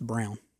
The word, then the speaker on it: Braun: Even though it looks like Braun, it's Brown. – What I say? Braun? – Braun. Oh, it, yeah, it, it does it, look like Braun. It's, yeah. it's spelled like Braun, but it's yeah. Brown. Okay.